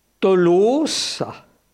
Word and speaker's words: Tolosa: les auteurs grecs l'écrivent tolosa », avec un omicron suivi d'un oméga, ce qui vous explique la prononciation Tolosa, la fermeture du second O, et c'est ça qui a donné finalement Toulouse et Toulouse.